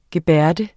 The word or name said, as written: gebærde